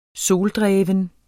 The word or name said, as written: soldreven